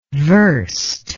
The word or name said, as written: versed